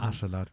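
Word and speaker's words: Askeladd: Ich finde diese ganze Entwicklung und, und Chemie zwischen den Thorfinn jetzt zum Beispiel und, und Aschelad. Ähm, hab ich, hoffentlich habe ich das gut ausgesprochen. Aschelad, sehr schön.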